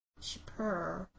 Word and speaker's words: Chypre: She apparently loved the scent of, I had to look up how to pronounce it, Chypre. Chypre. Chypre.